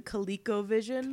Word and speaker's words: ColecoVision: The ColecoVision. – Coleco. It's like Khaleesi, just... low And not spelled anything like Khaleesi. – No, but Coleco. I'm saying the pronunciation of it is like that.